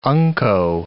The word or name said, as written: unco